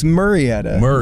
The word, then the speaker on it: Murrieta: It's Murrieta.